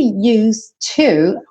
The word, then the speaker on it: used to: Então, esse used to, olha que interessante a pronúncia, né? Used to. Você não escuta esse passado, na verdade. Você escuta used to, porque used, não se pronuncia o E, certo? Embora a gente ainda não esteja na terceira parte da pronúncia, mas você já sabe, né, que você não fala used to. Você fala used.